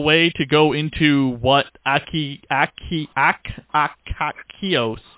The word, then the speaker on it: Akakios: A-K-A-K-I-O-S. A-K-A-K-I-O-S. Meanings, origin, and history of the name Akios. Akikakos. Akikos? Kakakokoko. – I struggled with how to pronounce this, and the closest thing I could even come up with was Akakios. That was the closest thing I could even relatively come up with.